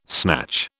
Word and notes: snatch: British English

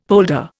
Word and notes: polder: Indian

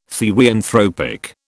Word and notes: therianthropic: British English

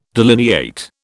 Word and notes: delineate: British English

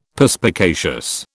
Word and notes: Perspicacious: British English

